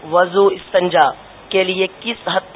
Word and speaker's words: وضو: وضو نہیں ہے, وضو واو پر پیش ہے.